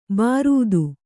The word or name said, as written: ಬಾರೂದು